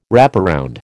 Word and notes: wraparound: American English

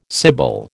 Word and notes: sibyl: American English

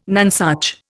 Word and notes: nonesuch: Indian

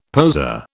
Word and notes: poser: British English

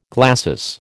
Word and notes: glacis: American English

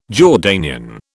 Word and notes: Jordanian: British English

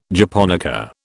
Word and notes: japonica: British English

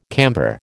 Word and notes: camper: American English

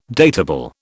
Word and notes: datable: British English

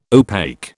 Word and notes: opaque: British English

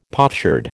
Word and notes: potsherd: American English

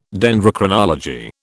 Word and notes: Dendrochronology: British English

